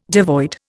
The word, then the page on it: devoid: Indian